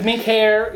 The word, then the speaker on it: Mincéir: No, it's minkir, which is singular. Or somebody plugs with it. There's minkiri, which is the collective.